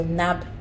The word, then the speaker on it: Nabhel: List, L-I-S-T. Yeah, and you can just search for Nabel. So my name is spelled N-A-B-H-E-L. So Nabel.